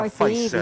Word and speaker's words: Pfizer: Pfizer. Ya lo digo bien, oh, ¿ah? Pfizer. sí, Bien, bien.